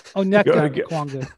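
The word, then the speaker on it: Onyeka Okongwu: Number six, Okenga Okwongwu. Number six, Okenga Okwongwu. – This is the worst pronunciation of his name of all time. – Onyeka Okwongwu. – Big O.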